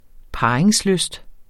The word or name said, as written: parringslyst